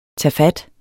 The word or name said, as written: tagfat